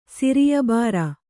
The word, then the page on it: ಸಿರಿಯಬಾರ: kannada